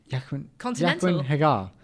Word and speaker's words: Jaqen H'ghar: Jaqen H'ghar? – Ooh. – Is that how you say his name? – Jaqen H'ghar.